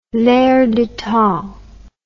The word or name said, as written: l'air du temps